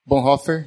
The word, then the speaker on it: Bonhoeffer: Bonhoeffer, essa é a pronúncia.